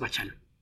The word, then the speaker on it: Bachalo: En fin, está Uncanny X-Men, que era la otra serie de Brian Michael Bendis con Chris Bacalo, o Bachalo, ¿cómo se pronuncia? No sé. X-Men, según Richard Starkings, es Bachalo. – Ok. – Me regañó dos veces por decir Bacalo.